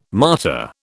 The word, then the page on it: martyr: British English